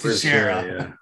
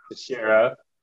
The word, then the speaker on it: Teixeira: Against Textera. – I think it's Blachowicz. – Blachowicz? – Blachowicz. – Blachowicz. – And Teixeira. – Teixeira. October 30th.